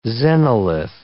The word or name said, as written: xenolith